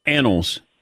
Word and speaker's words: Annals: There's a clip recording of you announcing the book title, and in that recording, there's one word ever so slightly mispronounced. – I know. – And I just wanted for you to confirm the word for me. It's called the occasionally accurate what a football? – Annals. – Perfect. Thank you. Appreciate that. – All right, Michael. Thank you. Yeah. Yeah. A-N-N-A-L-S.